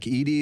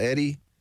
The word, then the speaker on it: Edey: Zach Edey, is that how you say his name? – Yep. – Eddie, just a great player for them.